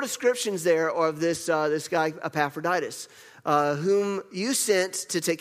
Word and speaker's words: Epaphroditus: Epaphroditus. Am I saying it right? Yes, Epaphroditus.